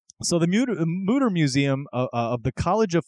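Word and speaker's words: Mütter: It's spelled Mutter, M-U-T-T-E-R, but the U has those two dots on top, like Motley Crue. So that's pronounced ooh, oh, yeah. Right? Like Mutter? – Or Thor's hammer.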